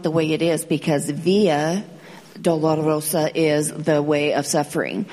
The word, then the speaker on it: Via Dolorosa: But his take on it was a lot of people mispronounce the name of that road. It's Via Dolorosa.